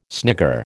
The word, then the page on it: snigger: American English